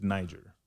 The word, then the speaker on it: Niger: Have y'all seen the new president of uh, and correct me if I'm pronouncing the name of this country incorrectly. I believe it's Niger mm-hmm.